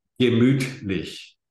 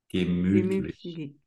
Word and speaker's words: Gemütlich: Gemütlich. Gemütlich.